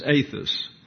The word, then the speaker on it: ethos: And it is pronounced the eta, that's E-T-A. The eta is pronounced as if it were a long A in English. And so this is not ethos or ethos, it's athos.